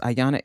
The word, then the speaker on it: Ayana: And on Instagram, it is Ayana Explains It All with an underscore between each word. And my name is spelled A-Y-A-N-A.